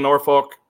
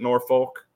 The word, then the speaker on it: Norfolk: Do people in Norfolk around that area, do they get offended if we pronounce it Norfolk? Because, you know, up there in Norfolk, they pronounce it Norfolk. It's kind of like the Missouri, Missouri thing, but just saying.